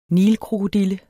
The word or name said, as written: nilkrokodille